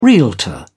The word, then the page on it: realtor: British English